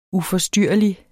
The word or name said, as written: uforstyrrelig